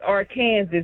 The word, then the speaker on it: Arkansas: If I say it right, it's Arkansas. It's not Arkansas.